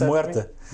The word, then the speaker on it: muerte: in eh, Spanish, pronounce muerte.